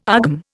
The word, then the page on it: AGM: Indian